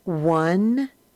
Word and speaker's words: one: Now, the words in the box are pronounced one, once, none, done.